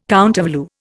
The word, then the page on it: countervalue: Indian